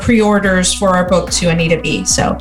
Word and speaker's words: Anita B: So that is Anita, A-N-I-T-A, the letter B, .org, for those who are wondering how to pronounce it, Anita B.